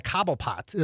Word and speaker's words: Cobblepot: And that's the thing about like those Batman movies is that you have a villain, Cobblepot.